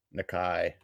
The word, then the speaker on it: Nikkei: How do you pronounce that? – Nikkei.